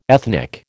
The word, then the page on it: ethnic: American English